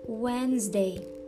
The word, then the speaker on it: Wednesday: Quarta-feira, tem gente que tem muita dificuldade de pronunciar essa palavra porque a escrita dela é um pouco estranha, mas ignora a escrita, foca na pronúncia, foca no jeito de falar. Wednesday, quarta-feira.